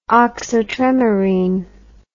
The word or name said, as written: oxotremorine